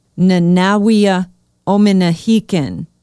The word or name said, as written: Nanaweyah Ominihekan